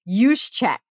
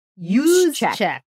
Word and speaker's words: Juszczyk: I'm like, how do you pronounce Kyle J. football man's name whose wife makes jacket? He goes, it's Juszczyk. – Juszczyk.